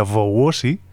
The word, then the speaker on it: Wołosi: dado que aunque se escriba Wołosi, se pronuncia Wołosi, la L tiene como una rayita.